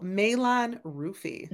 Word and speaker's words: Malon-Rufi: Oh, Malon-Rufi.